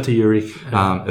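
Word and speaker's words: phosphaturic: So you will get an increased phosphate excretion in the urine, or a phosphoturic. I think that's how you pronounce it. – Yeah, phosphoturic effect. – Yeah, that's right.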